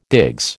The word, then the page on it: digs: American English